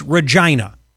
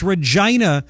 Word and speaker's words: Regina: It's Regina. Get your mind out of the gutter. It's Regina. Regina. That's what it's Regina, Saskatchewan. A lot of Canadian snowbirds.